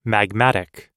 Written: magmatic

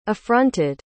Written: affronted

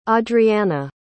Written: audrianna

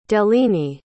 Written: deline

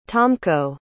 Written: tomko

